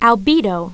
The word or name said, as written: albedo